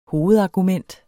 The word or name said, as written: hovedargument